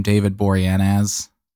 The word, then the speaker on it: Boreanaz: David Boreanaz.